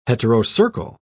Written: heterocercal

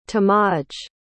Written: tomaj

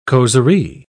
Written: causerie

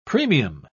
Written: premium

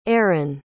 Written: aeryn

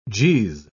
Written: jeez

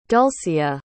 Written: dulcea

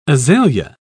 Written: azalea

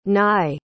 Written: nye

